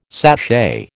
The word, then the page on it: sachet: American English